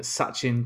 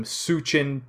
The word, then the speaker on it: Sachin: And he pronounced the most famous Test match cricket player of all time, Sachin Tendulkar, but he called him Suchin Tendulkar, which is very funny.